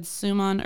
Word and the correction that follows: Šuman: earlier. I think it's pronounced Schumann. – Really? – Because it has that weird little S, that accent thing over the S.